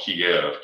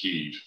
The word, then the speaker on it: Kyiv: Many Americans call it Kiev, K-I-E-V. It's actually pronounced Kyiv.